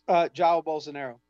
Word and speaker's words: Jair Bolsonaro: Jair Bolsonaro.